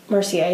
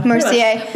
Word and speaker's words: Mercier: Laura Mercier. Did I say that right? – Mercier, yeah. – Mercier.